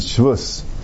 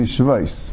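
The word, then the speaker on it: shvais (שבות): You find the Gemara says we usually pronounce it shvus. So asim shem shvus. We say the word shvus means an issue in the Rabbanan. But the correct nekudis would be shvais.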